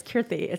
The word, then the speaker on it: Kirti: Okay, it's Kirti. It's a soft T. – Kirti.